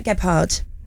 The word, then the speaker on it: Gebhard: Gebhard. Because we go hard, we go home. I was like, is it a silent H earlier?